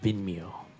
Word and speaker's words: Vimeo: Vimeo.